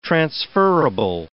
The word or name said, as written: transferrable